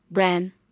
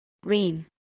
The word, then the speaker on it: brain: Many students would say bran, which is not right. What can you do to pronounce it correctly? You should start by saying ray. Again, ray. One more time, ray. Then slowly add N after it. Now, it should sound like rain.